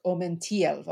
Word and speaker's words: omentielvo: He puts the emphasis in omentielvo on the second part. It's like omentielvo, or it's not quite.